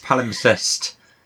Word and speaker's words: palimpsest: Um, yeah, it's a kind of overlaid um, palimpsest. Is that how you say it? That's a proper academic word. Pal- palimpsest. palimpsest.